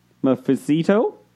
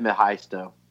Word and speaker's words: Mephisto: So, I know I'm going to butcher that name, but the options are Wanda herself, Mephisto. – Jeez. It's pronounced like it's spelled.